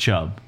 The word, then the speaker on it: Chubb: Just stop the, right The Nick Chubb is the correct way to pronounce his name.